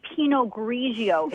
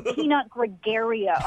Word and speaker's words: Pinot Grigio: You don't even know how to say Pinot Grigio. It's not Pinot Gregario.